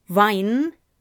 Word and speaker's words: Wein: Because actually, vine, the plant, is exactly the same pronunciation as wein, the drink in German.